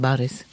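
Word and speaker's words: Baris (Борис): O zu spricht man A aus? – O spricht man A aus, ja. Okay, das äh, Also Boris heißt dann Baris.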